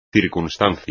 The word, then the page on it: circunstancia: espagnol